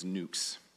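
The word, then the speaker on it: nux (νύξ): It's N-Y-X, but the pronunciation is nux.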